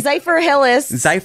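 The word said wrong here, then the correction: Zephyrhills: Zypher Hills.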